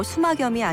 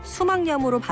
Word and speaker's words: 수막염: 수막의 염증도 수막염이 아니라 수막염으로 발음해야 맞죠.